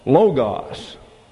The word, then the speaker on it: Logos: You'd have an accent there, L-O-G-O-S, spelled the same way. But an omega is a long O, and when you bring an omega into the English, it's pronounced like an English long O, Logos. And when it's a short O, an omicron, it gets an A sound in the English, a short A, Logos. And it's accented on the first syllable. So Logos, Logos, Logos, it's L-O-G-O-S.